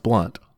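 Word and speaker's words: Blount: I think it's James Blunt also, but it's B-L-O-U-N-T and it's pronounced Blunt.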